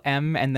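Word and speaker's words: MØ: I've been told it's a capital M and then O with the slash through it. – Yes. – I've been told it's pronounced Ma. – So have I. I think we're going to go with that.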